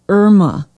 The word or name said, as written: irma